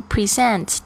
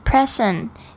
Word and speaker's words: present: present，特别注意一下这个 present，它有两个发音，一个是 present，一个是 present。